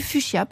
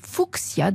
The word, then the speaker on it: fuchsia: C'est le cas du Fuchsia, par exemple, originaire d'Amérique du Sud, qui peut aussi se prononcer Fuchsia d'ailleurs, car il a été ainsi baptisé en l'honneur de Leonard Fuchs, F-U-C-H-S, un des pères de la botanique.